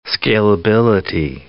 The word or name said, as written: scalability